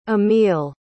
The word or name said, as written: amil